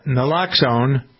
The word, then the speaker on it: Naloxone: This organization that put this out, Naloxone, N-A-L-O-X-O-N-E, I guess it would be pronounced Naloxone, right, is a medication that reverses an opioid overdose.